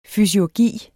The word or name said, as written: fysiurgi